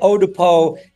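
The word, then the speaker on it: Eau de Pot: I wanted to call it Odepo, E-A-U, D-E-P-O, with a T is silent, which is part of the lyric in the song.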